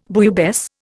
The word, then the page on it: bouillabaisse: Indian